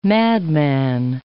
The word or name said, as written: madman